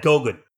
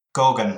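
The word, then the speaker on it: Goguen: I'm not sure how you pronounce that word. – Yeah, Gorgon. – Gorgon, right, Gorgon.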